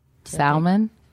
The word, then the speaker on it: salmon: Then when he orders dinner, he pronounces salmon with a L. – Salmon?